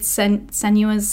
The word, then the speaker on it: Senua: It was the Hellblade Senua's Sacrifice. Is that how you say your name? – Senua, yes.